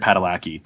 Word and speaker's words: Padalecki: Jared Padalecki.